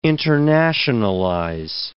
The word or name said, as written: internationalize